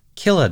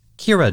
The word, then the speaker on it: Killah: dated December 86 and named Killa Doga, written K-I-L-L-A-H, and rendered as Kira Doga, with a short O sound in the katakana.